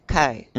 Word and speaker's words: chi: That letter is pronounced chi in Greek.